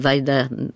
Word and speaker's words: Wajda: Oui, Andrzej e, Wajda, je, je, le prononce en polonais. – Oui, oui, c'est moi qui le prononce à la française.